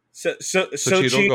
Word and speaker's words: Xochitl: Because I want to say Xochitl, because I know in Portuguese, the X is a ch sound, but I know that it's not.